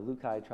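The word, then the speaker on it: Rukai: the Lukai tribe, it's spelled with the R, the Lukai tribe, and then the Dao tribe, spelled T-A-O.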